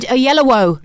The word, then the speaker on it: Oyelowo: David Oyelowo.